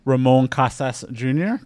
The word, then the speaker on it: Ramon Casas Jr.: Our guest today is District 1 City Council candidate Ramon Casas Jr. Did I pronounce that correctly? – Correct.